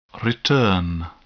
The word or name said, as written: return